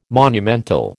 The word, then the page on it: monumental: American English